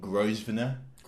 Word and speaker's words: Grosvenor: Yesterday, me, Jack, and a couple of the other lads went to the Grosvenor.